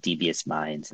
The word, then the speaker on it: DVS Mindz: The book, and again, for those listening at home, it's called Devious Mindz, and that's spelled DBS, by the way, Devious Mindz,